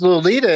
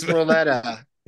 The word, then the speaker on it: Loleta: And it's Lolita, isn't it? – You keep calling it that because I think it's Loletta. – Yeah, it's spelled L-O-L-E-T-A.